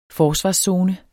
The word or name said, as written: forsvarszone